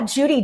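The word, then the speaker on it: Judi: Yeah, Judy, J-U-D-I. I'm Judy with an I. And my last name is Holler, H-O-L-L-E-R, Holler.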